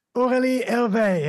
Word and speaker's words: Aurelie Hervert: I have a fantastic guest here for episode 205 of Playing with Perspective, the suspended animation podcast, Aurelie Hervert. Have I pronounced your name correctly? – Perfectly.